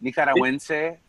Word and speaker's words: Nicaragüense: Nicaragüense. If you want to try it, it would be how you say it in Spanish.